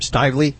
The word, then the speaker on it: Stively: Stively.